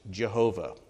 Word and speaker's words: Jehovah: It's traditionally pronounced Jehovah, occasionally now Yahweh.